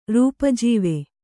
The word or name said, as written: ರೂಪಾಜೀವೆ